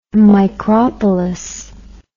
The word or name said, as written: micropolis